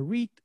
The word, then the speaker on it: REIT: What is the major difference between a REIT and how does it work? – Sure. Well, you've already learned something because you pronounce it right. It is REIT. So that's correct.